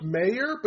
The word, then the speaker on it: Meijer: He goes, it's pronounced Mayor, but he spells it with a J. It should be Major.